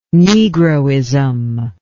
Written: negroism

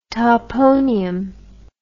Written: toponium

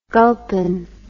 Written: gulpin

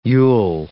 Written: you'll

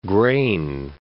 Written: Grain Coast

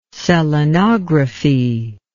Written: selenography

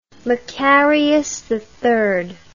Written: Makarios III

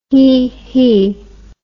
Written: heehee

